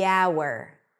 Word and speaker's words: hour: The hour. Why? Because the H is not pronounced and we're actually starting with a vowel.